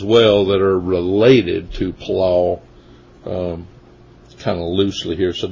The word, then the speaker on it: palal: It's pronounced palal. P-A-L-A-L is how it's spelled in the transliterated English word version.